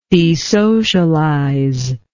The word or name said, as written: desocialization